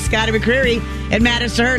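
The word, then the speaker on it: Scotty McCreery: I believe it's pronounced Scotty McCreary.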